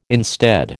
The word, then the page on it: instead: American English